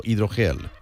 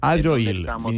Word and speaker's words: HydroHeal: No sé si lo he dicho bien o no lo he dicho bien. ¿HydroHeal es o HydroGel? – Es HydroHeal. – HydroHeal, ni una ni la otra.